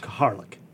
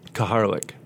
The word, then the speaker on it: Kaharlyk: And it is spelled K-A-H-A-R-L-Y-K. – That's correct. – Kaharlik. – Kaharlik.